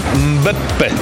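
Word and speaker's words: Mbappé: Karola, ja to rozumiem i pozdrawiam kibiców razem z tobą i ciebie też pozdrawiam, ale w tym miejscu trzeba przypomnieć o poprawnej wymowie tego nazwiska. – Mekambę, jak on? – Mbeppe. – Mbeppe.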